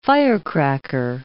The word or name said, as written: firecracker